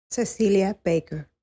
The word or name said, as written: cecilia baker